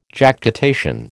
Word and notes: Jactitation: American English

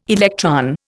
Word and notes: electron: Indian

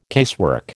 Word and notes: casework: American English

